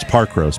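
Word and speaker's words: Parkrose: Pack Rose in Portland, Oregon, too. They don't say it like that. It's Park Rose.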